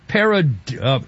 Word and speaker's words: pareidolia: The Planetary Society is saying it's nothing more than an optical illusion, a case of pareidolia. – Yeah. – Pareidolia, is that how you pronounce it? Pareidolia? – Something like that, yeah.